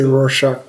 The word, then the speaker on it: Rorschach: I always say Rorschach, but I'm sure I mispronounced. That's right. – I'm pretty sure that's right.